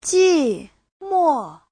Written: ji4mo4

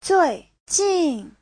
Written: zui4jin4

